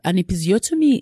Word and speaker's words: episiotomy: Is it, and I might not even pronounce it correctly, an episiotomy? – Episiotomy, yes.